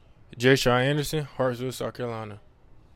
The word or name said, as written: J'Shawn Anderson